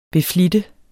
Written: beflitte

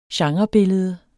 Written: genrebillede

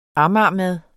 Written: amagermad

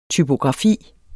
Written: TYPOGRAFIER

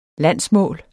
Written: landsmål

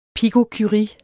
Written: pikocurie

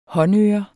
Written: håndører

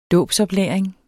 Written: dåbsoplæring

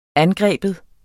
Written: angriber

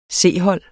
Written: C-hold

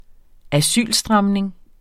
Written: asylstramning

